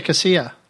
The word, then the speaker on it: Kasia: I think you say Kasia. – Kasia? Okay. K-A-S-I-A.